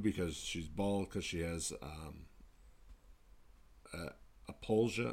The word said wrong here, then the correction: alopecia: I don't know how you pronounce it. – Alopecia. – Alopecia, yes. And next thing you know, Will Smith walks on stage and slaps the shit out of Chris Rock.